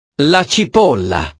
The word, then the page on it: la cipolla: italiano